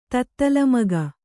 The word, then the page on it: ತತ್ತಲಮಗ: Kannada